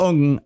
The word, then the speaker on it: Ng: Nigel Ung, it's spelled NG, has got this character, Uncle Roger...